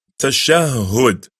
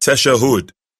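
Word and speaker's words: tashahhud: As for the 10th pillar of salah, is the recitation of the final tashahud. Remember, it's not tashahud. It's pronounced tashahud.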